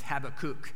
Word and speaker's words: Habakkuk: Yeah, some of you know him, who says that you're supposed to pronounce his name as Habakkuk, like have a cookie.